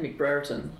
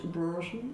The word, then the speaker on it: Brereton: What's his name? McBrereton. That's what he was saying in the first half. He was like, I believe it's pronounced Brereton.